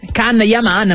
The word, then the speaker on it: Can Yaman: Ah, Gianni Aman. – Come? – Gianni Aman. – Gian? – Can Yaman, ma si legge Gian.